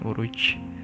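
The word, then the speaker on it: Uruç: Uruj Uruj U pakai Y itu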